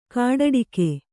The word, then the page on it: ಗೋಟಡಕೆ: gōṭaḍake